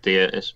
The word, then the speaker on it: dia: I was just going to say add to what Crow was saying, then in Gaelic, dia is God, D-I-A, pronounced dia, and a bit like deity, but it's also how you greet someone.